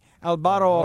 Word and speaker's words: Alvaro: You guys all missed the first V in the Alv. It's more like a B. Alvaro Jacobo.